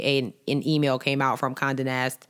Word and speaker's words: Condé Nast: And the company, which is, I don't know if I'm even saying this right, it's Condé Nast. Mm-hmm. I know it's just an accent over the E. – I'm telling you, it's a French, Condé Nast.